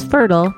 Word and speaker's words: Fertyl: That's Fertyl, pronounced Fertyl, but spelled with a PhD out of spite.